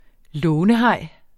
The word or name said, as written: lånehaj